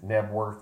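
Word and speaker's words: Knebworth: What is it, Nebworth? Am I saying that correctly? The stadium? – Oh, yes.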